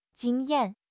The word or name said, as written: jing1 yan4